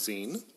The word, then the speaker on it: zine: It's pronounced Zine. – Zine? So Zine. Yeah. Zine. Mm-hmm. Okay. So, yeah, and it comes from the word fanzine. Fanzine. Mm-hmm. Kind of like magazine, the same pronunciation.